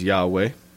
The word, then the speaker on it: Yahweh: We pronounce it as Yahweh.